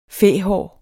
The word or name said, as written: fæhår